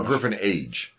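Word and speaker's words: griffonage: But it's G-R-I-F-F-O-N-A-G-E. It's literally Griffin Age. – Yeah. – Okay. It's Griffinage.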